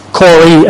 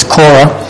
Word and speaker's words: Korah: And then we're looking at the third one today, Corey, as he puts it in the New Testament, but I'll be pronouncing it as Korah.